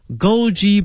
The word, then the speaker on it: Goji: Goji Berry 啊， Goji G-O-J-I 跟我们汉语的发音是差不多的。 Goji Berry 啊， G-O-J-I 后面加一个 Berry B-E-R-Y 啊，这个词合到一起呢叫做枸杞啊， Goji Berry。